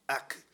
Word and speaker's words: ak: We don't see it in the English translation, but in the Hebrew translation, there is a Hebrew word, and we would pronounce it ak, A-K.